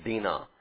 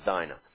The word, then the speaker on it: Dinah: You pronounce this Dinah in Hebrew, but it's the name that we're used to seeing and pronouncing as Dinah.